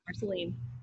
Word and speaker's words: Marceline: And so I don't know if I'm going to pronounce this correctly, but you're from Marceline. – Yes. – Correct? – Okay. Yes, Marceline. Nice work.